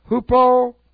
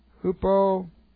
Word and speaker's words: hupomone: Hupomone. I love this word. Hupomone. That's H. This is a rough reading, so you get an H. H-U-P-O, hupo, M-O-N, long E, hupo, mone.